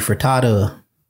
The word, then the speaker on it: Furtado: Um, during this tour, he was able to collaborate with artists like Nelly Furtado, Furtado. Hmm, I think I said it right the first time.